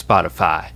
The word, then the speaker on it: Spotify: Ooh, a little surprise in the Ear Biscuit variety in your Spotify. That's not how you say it. It's Spotify. – Yeah. – I just wanted to say you tried to rhyme variety and Spotify.